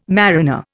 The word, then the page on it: mariner: Indian